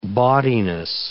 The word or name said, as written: bawdiness